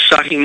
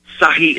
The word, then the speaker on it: Sahih: In English, if you want to write down these two Hadith collections, one is Sahih Muslim. Sahih is spelled S-A-H-I-H.